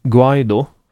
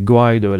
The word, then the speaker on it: Guaido: Det är lite osäkert på om man säger Guaido eller Guaido eller Guaido låter inte spanskt. – Jag har hört inspelning nämligen av spansktalande människor som definitivt lägger betoningen på andra stavelsen.